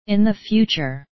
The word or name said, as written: in the future